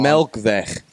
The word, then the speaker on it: Melkweg: I'm your host, Darren, and right now we're sitting in the back green room at Melkweg, even though I haven't been able to get that pronounced right all night long. – Melkweg.